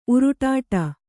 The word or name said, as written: ಉರುಡಾಟ